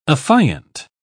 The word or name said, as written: affiant